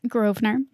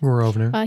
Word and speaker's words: Grosvenor: Charles Grosvenor, whose name I apologize if I ever mispronounce, but I did look it up and that seems to be the way to pronounce it, so I'm going to go with it.